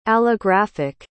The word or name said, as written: allographic